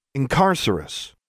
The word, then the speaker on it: Incarcerous: "Incarcerous," with falling stress on the second syllable, Alexandra said.